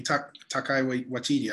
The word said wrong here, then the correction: Taika Waititi: I don't know if I'm saying that name right. Probably butchered it. – Taika Waititi. – Taika Waititi. I'm sorry.